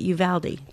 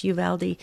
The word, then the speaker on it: Uvalde: So Stacy called in and said, you want to pronounce the town in Texas, Uvalde, it's Stacy. We got it. Uvalde. Thank you so very, very much. Uvalde, Texas.